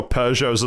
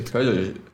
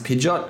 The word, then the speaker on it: Peugeot: Peugeot. I always thought that Peugeot was like pronounced Pidgeot because of like the...